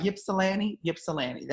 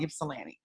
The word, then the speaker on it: Ypsilanti: Ypsilanti. That's what it's called. Ypsilanti.